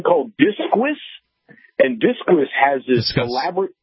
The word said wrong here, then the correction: Disqus: Discuss, is that what it's actually called? – Okay. D-I-S-Q-U-S. – It's pronounced Discuss.